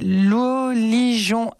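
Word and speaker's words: Luo Lirong: Vraiment, c'est Lou Li Jong Art sur Instagram, oui, et Lou Li Jong sur Facebook. – Oui. – Ça s'écrit... Alors, le Jong, je parle presque chinois, c'est R-O-N-G. Ne vous trompez Exactement. – Pas. Exactement. Donc, L-U-O, Lou Li Jong, L-I...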